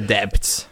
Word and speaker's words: debt: I want to repay the debts. – You don't have to pronounce the B in debt.